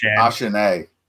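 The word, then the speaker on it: Achane: Of course, Achan, pretty sure that's what Snowman said. That's how you say his name. Oh, yeah, that's right. Achan. We would just call him Achan.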